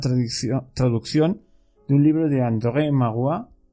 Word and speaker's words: André Maurois: Os puedo asegurar que he puesto el Google Traductor francés para escuchar la vocecilla esa que lo lee, para saber cómo se pronunciaba. Si lo, lo leéis, pon, así pronunciado en castellano es André Maurois, un famoso escritor francés.